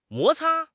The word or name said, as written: 摩擦